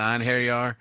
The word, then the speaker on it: Einherjar: Einherjaren. If you want to get technical, about it, you got to roll that R. I just call it the Einherjar, but it's actually Einherjar is for singular, and Einherjaren is for plural.